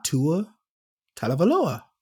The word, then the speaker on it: Tua Tagovailoa: He's not Tua Tagovailoa.